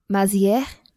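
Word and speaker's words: Mazières: Today on the show, I am so excited to have David Mazieres. Did I say that right? – No, it's French. It's Mazier, actually.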